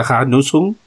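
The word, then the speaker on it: Hornussen: Es eso El que, mm, el que voy a hablar hoy se llama, nosotros lo leeremos Harnusen, se pronuncia Harnusen, y empezó en el siglo XVII, en la zona de Berna y Salutois y Argovia.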